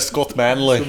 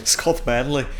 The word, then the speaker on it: Scott Manley: A první věc, co mě jako zaujalo, byla tvoje jako nedostatečná výslovnost Scotta Manleyho, jo. Že to je Scott Manley. Scott Manley.